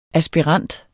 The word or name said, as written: aspirant